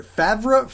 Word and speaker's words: Favre: Favre? Favre? Favre? – Favre. Favre. Yeah. Okay. It's like the cheese.